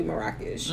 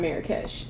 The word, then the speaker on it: Marrakesh: He said, no, it's pronounced actually Marrakesh, not Marrakesh.